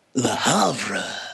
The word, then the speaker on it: Le Havre: Le Havre.